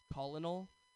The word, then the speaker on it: colonel: I literally last year found out that C-O-L-O-N-E-L is not pronounced colonel. It is, in fact, pronounced colonel.